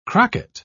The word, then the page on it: crocket: British English